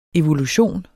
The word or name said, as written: evolution